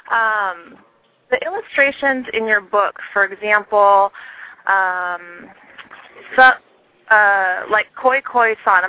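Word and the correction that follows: Cio-Cio-San: I'm not sure if I'm pronouncing that correctly. – Cho Cho San, yeah. – Mm-hmm. Cho Cho, okay.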